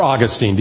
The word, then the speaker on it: Augustine: There was a man in August of 386, and his name was Aurelius Augustine. Or Augustine, depending on how you say it.